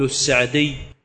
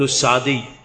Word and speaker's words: السعدي: والعلامة السعدي رحمه الله تعالى يختلف بعض المعاصرين في نطق اسمه، فبعضهم يقول السعدي وبعضهم يقول السعدي. وقد سألت في ذلك بعض من هو من قبيلته، فأخبر بأنهم داخل القبيلة بفروعها، يعني في الموضع المحلي، ينطقونها بالكسر فيقولون السعدي، وأما خارج ذلك الإطار فإنها تنطق بالفتح فيقال السعدي. قال رحمه الله تعالى في هذه الخلاصة، وهي تيسير اللطيف المنان، خلاصة تفسير القرآن.